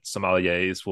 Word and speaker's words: sommeliers: Where that, that's that's that's a fancy word that sommeliers will will say. Oh wait, I, I pronounced it right.